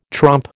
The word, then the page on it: trompe: American English